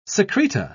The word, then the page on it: secreta: British English